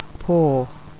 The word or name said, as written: poor